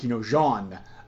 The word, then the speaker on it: Jean: I guess we use the French like pronunciation here, Jean, the frog person, yes.